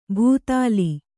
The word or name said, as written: ಭೂತಾಲಿ